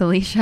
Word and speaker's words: Alecia: It's Alicia Hasselton. It's spelled A-L-E-C-I-A. And Hasselton is H-A-S-E-L-T-O-N.